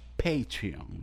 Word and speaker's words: Patreon: pero quiero que sepan que viene Patreon. ¿Lo dije bien? – Se jodieron, muchachos. es lo que quiero decir. Se jodieron. Que yo lo voy a llamar. – Lo dijiste muy bien, lo dijiste muy bien.